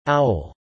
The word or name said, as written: owl